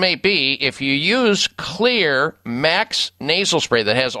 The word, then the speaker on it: Xlear: It's real simple when you're using natural nasal sprays that have an effect that's positive, like Clear Nasal Spray. X-L-E-A-R, that's how it's spelled. The X stands for xylitol, one of the components in their nasal sprays.